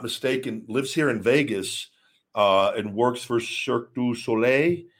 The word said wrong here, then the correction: Cirque du Soleil: I don't know why I said it like that. I always had trouble pronouncing that Cirque du Soleil. Cirque du Soleil, where I believe he's one of the bases and like holds people and does really cool feats of strength.